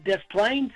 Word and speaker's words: Des Plaines: Yeah, I'm going to try to get out and see you at Des Plaines and my old stomping ground and give him some more of my money. But is that how it's pronounced, Des Plaines? – Des Plaines, yeah.